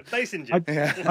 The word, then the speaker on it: Basinger: Is it Basinger or Basinger? – Basinger, I think. Yeah. – We had him on about 2 months ago. – Did we say his name, Dad? – Basinger.